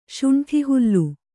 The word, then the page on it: ಶುಂಠಿಹುಲ್ಲು: kannada